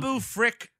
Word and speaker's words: Babu Frick: And not only that, Babu Freak, or whatever his fucking name. Babu Frick.